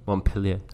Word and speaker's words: Montpellier: How did I pronounce Montpellier. – This? Montpellier.